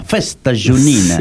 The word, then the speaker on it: festa junina: Festa junina.